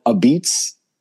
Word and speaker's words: apizza: But yeah, they're like Sally's, and they don't call it pizza. They call it a beats, but it's spelled like A-P-I-Z-Z-A, so a pizza. But for whatever reason, they say a beats.